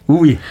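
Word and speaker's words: Oui: 아, 원래 발음은 우이인데요. 아, 저희는 잘 들어보면 오이로 들리기도 합니다. 우이, 니콜렛 라슨입니다.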